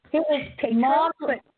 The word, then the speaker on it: Tatro: Is that how you pronounce it? Tatro. – It's Tatro. Yeah.